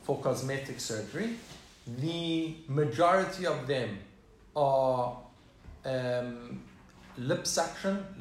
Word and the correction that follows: liposuction: Am I saying that right? – Liposuction. – Liposuction.